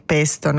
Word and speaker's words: pesto: Spezie, erbe, aglio, sale, pinoli, mandorle, infatti per il pesto, no? La genovese è fondamentale. – E si chiama pesto perché lo pesti. – Giusto. – Sì. Si pronuncia con la E chiusa in italiano.